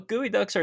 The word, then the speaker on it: geoduck: So, geoducks are a species of large saltwater clam found in the Pacific Ocean, as we already mentioned. I'll say again, it is spelled geoduck, but is pronounced as geoduck.